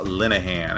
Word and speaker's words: Linehan: And we are here uh, with a special guest from none other than USA Water Polo, um, and that is Joe Linehan. And I'm, I'm pronouncing that right, right, Joe? – That is that is correct.